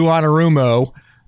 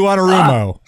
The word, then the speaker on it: Anarumo: Speaking of broken, the Colts are hiring former Bengals defensive coordinator Lou Anarumo for their new head coach.